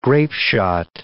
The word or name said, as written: grapeshot